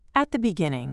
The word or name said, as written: at the beginning